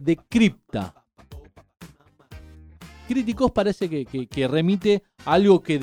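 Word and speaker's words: criticos (κρυπτικός): La palabra griega es criticos, con acento en la O, criticos.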